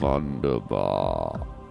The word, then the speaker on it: Wunderbar: Wunderbar. – It's with a V. Wunderbar.